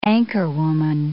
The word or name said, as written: anchorwoman